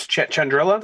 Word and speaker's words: Chandrila: It looked like school activity, but it's from her old planet, which is Chandrila.